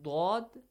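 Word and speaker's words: Dod (ض): But Dod, even if it has kasra, again, it has that heavy, like sound, it is heavily pronounced.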